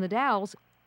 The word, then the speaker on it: The Dalles: Tell us a little bit first where the Dalles, it's spelled differently than it's pronounced, but where is the Dalles?